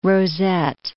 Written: rosette

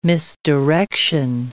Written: misdirection

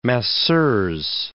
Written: masseuse

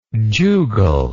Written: jugal

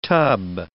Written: tub

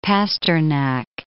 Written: Pasternak

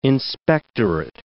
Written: inspectorate

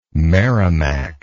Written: merrimac

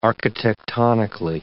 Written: architectonically

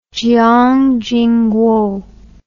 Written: Jiang Jingguo

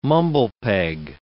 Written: mumble peg

